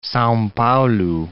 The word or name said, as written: Sao Paulo